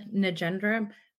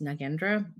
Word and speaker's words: Nagendra: My name is Cynthia Nagendra. Also, it's pronounced Nagendra, but I have been using both lately.